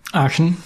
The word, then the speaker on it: Aachen: Did I say that right? Is it Aachen? – Aachen, yeah. – Aachen, okay, I'm getting there.